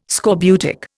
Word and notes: scorbutic: Indian

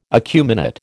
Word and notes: acuminate: American English

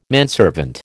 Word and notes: manservant: American English

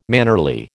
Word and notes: mannerly: American English

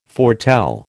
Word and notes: foretell: American English

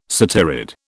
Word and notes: satyrid: British English

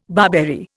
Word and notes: barberry: Indian

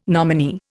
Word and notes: nominee: Indian

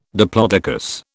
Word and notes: diplodocus: British English

